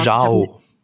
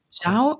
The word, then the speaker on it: Zhao: Zhao. – Zhao? Nee, ich glaube, also ich habe einen Artikel gelesen, dass es wie Jaw ausgesprochen wird.